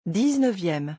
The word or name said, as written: 19eme